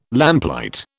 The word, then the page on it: lamplight: British English